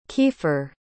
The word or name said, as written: Keefer